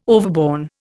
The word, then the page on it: overborne: Indian